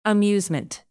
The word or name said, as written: amusement